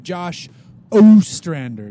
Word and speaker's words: Ostrander: I'm your host, and joining me today is Josh Ostrander.